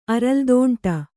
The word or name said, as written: ಅರಲ್ದೋಂಟ